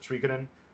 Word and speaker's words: Suikoden: In Japanese, it effectively is pronounced Suikoden, which is why it's named that.